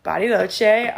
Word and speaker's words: Bariloche: I am sitting in Bariloche, Argentina.